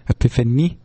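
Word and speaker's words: Epiphanie: And it's Epiphany with an I-E at the end, not a Y, because they're French, and I guess they want you to know it. So it's like Epiphany, Epiphany. It is pronounced differently because it's French.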